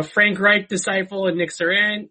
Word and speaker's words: Sirianni: They have decided to hire Colts offensive coordinator Nick Sirianni, I think it is. – I have never heard that name pronounced. I was hoping you knew it. – I think it's Sirianni.